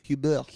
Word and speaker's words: Hubert: So the E and the R and the T. The T is silent, but you've got to almost do this movement with your mouth. You've got to go Hubert.